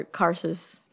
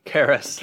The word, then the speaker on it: Karras: And while Father Carsus. – Karras.